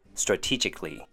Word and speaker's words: strategically: And our last one would be strategically, five syllables. This sounds like four syllables, but it's actually five. First is straw, which has the short A sound. T sounds like T-E-E, like a t-shirt.